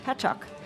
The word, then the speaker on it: Patchogue: And coming up on Barcore Radio, we will be traveling across the waters all the way to Patchogue. Is that how you say it? – Patchogue.